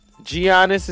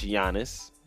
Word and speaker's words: Giannis: Giannis is how some people say his name. His name is actually pronounced Giannis. Look it up.